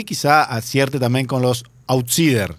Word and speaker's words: outsiders: Veo que andan nombres dando vueltas y gente que se está reuniendo para el outsiders, outsiders. – Sí. – ¿Se dice así? – Exacto. – Outsiders.